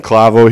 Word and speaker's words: Clavo: We have Jason Clavo here.